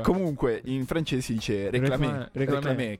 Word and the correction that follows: réclame: che vuol dire richiamare l'attenzione. Cioè, per cui ogni lingua c'ha la sua. – Io avrei detto réclame. Ho visto l'accento sulla E. – Ah, bravo, sai che c'hai ragione.